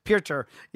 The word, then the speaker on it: Piotr: And first, I love the fact that you have gone with a traditional pronunciation instead of the Americanized bastard version that we do of Peter Jan when it's Piotr Jan.